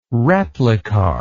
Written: replicar

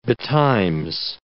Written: betimes

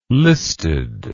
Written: listed

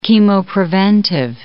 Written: chemopreventive

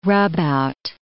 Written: rubout

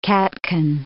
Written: catkin

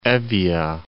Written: Evvoia

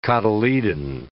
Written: cotyledon

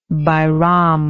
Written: bairam

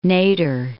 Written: Nader

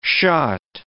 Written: shot

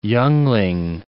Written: youngling

